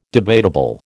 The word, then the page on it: debatable: American English